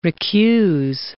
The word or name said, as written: recuse